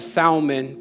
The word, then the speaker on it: salmon: and Nahshon was the father of Salmon. By the way, let me just put a quick pause there. If this was the fish, the L is silent.